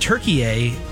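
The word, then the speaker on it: Türkiye: Yeah, it's Turkey, but everybody's pronouncing it Turkey A. All the announcers, I think that's the proper pronunciation for the country, so I decided to go with that pronunciation.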